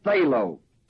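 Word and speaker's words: thelo (θέλω): The Greek word is the word thelo, spelled T-H-E-L-O, long O.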